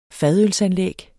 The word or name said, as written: fadølsanlæg